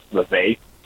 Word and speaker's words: Vivek: It's pronounced Vivek.